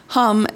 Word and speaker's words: Ham: But so when you just write So Hum as in H-A-M, it looks like ham. But, you know, if you know the word, you know it's pronounced hum.